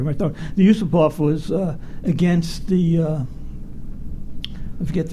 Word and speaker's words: Yusupov: But you have a scene where Kirk Johnson is talking with Felix Yusupov, I think is how he pronounced it. – Yeah. Prince Yusupov. Yeah.